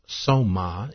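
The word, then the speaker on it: soma (σῶμα): A body, pronounced soma in the Greek, a spirit, pronounced pneuma in the Greek, and a soul, pronounced psuche in the Greek.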